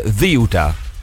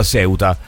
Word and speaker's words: Ceuta: Come se ci fosse il TH inglese, Ziuta. Okay. Ziuta. Con l'accento rigorosamente sulla prima sillaba, ragazzi.